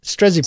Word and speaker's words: Strzepek: Strezbeck is spelled S-T-R-Z-E-P-E-K. – Strezbeck. – So that, yes, Strezbeck is how it kind of looks. – Strezbeck. – Yeah. And it's basically pronounced like that first Z and E are flipped.